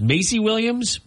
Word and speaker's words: Maisie Williams: And if you're a fan of Game of Thrones, I think it's pronounced Maisie Williams.